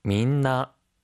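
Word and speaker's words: 민나 (みんな): 모두, 다, 민나, 민나. – 민나, 모두, 다라는 뜻이에요. 원래는 미나였는데요. 사이에 발음이 들어가서 민나가 되었습니다.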